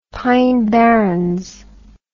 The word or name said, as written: pine barrens